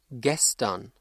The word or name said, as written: gestern